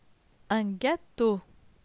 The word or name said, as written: Un gateau